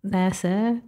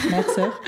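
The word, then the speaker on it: Mère Sœur: And they're like, how do you say it? And I'm like, Mere Soeur. – Mere Soeur.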